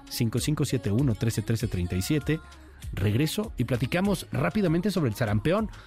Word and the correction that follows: sarampión: sobre el sarampión, perdón, sarampión, ya lo pronuncie bien, sobre el sarampión, la otra vez, sarampión.